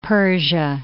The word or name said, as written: Persia